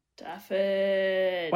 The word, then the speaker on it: Dafydd: Oh, Dafydd.